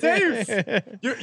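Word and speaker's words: Mateusz: You had it right the first time again. Matheus.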